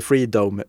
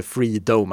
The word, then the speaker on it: Freedom: Och jag säger inte Freedom eh, med konstigt engelskt uttal, utan den heter Freedom, alltså som en... Eh, det är en anspelning på freedom, men Freedom mm, som en... – Ja. Eh, ja som en kupa på slutet.